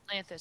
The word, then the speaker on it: Lanthus: We know from Nesta's vision from Lanthus, how did we decide to say his name? – Lanthus sounds good.